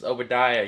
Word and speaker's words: Obadiah: And I'd be like, oh, it's pronounced Obadiah. You can just call me Obi.